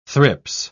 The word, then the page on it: thrips: British English